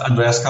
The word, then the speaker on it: Andreas: Yes, it's Andreas Kalcker.